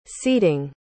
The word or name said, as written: seeding